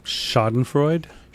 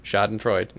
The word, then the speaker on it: Schadenfreude: How do you say that word, bud? – Schadenfreude. – Schadenfreude, not Schnegen, but no.